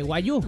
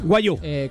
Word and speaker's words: Wagyu: ¿Cómo era? ¿Wagyu? ¿Cómo se dice? – Wagyu. – Wagyu.